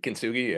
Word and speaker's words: Kintsugi: You've introduced, I'm going to butcher this name, Kintsugi? – Kintsugi, yeah.